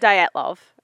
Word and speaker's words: Dyatlov: Because when you research, how do you say it? – Dyatlov. – Oh, I don't say it like that. Dilatov Pass. – Some people say Dilatov. – Yeah. That's how I say it. Just flatten it right out. Dilatov Pass.